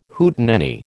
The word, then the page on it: Hootenanny: American English